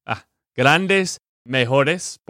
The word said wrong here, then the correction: mejoras: por el año. Mejoras. – Mejoras. – Mejoras. – Ok. – Con una A. Dilo de nuevo. Mejoras.